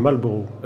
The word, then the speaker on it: Marlborough: Comme vous l'avez parfaitement prononcé à l'anglaise, son nom, je vais me permettre, Ian, de le prononcer très mal à la française, sinon on ne comprend pas, c'est le duc de Marlborough.